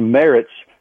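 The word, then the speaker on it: Marz: He finally cleared that up, saying it's M-A-R-Z. Looks like Mars. He said it's Merritt, just like carrots, but with an M.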